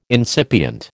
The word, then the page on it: incipient: American English